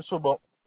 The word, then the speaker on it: Sobhan: Monsieur Sauban. – Comment ça s'écrit, Sauban? – S-O-B-H-A-N. – S-O-B-H-A-N.